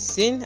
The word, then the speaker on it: Syn: Once again, my name is Syn, and Syn is spelled S Y N.